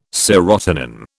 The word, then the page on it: serotonin: British English